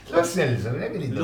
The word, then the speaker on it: Lo Celso: El otro día me enteré de que Lo Celso, su apellido, no lo pronuncia como lo pronunciamos todos. Él sí, dice Lo Celso.